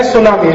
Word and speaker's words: tsunami: जैसे एक अंग्रेजी शब्द है सुनामी, तो बोलते हैं सुनामी, लिखते क्या है TSUNAMI, तो बोलना चाहिए टी सुनामी। कहते हो जी टी साइलेंट है तो क्यों लगा दिया, हटा दो वहां से अगर साइलेंट है।